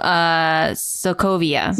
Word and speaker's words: Sokovia: And I guess I didn't notice it for the Slovakia. Am I saying that right? – Oh, Sokovia.